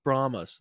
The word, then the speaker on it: Brahmas: Brahmas? Am I saying that right? The San Antonio. – You're nailing it. – Brahmas. There we go.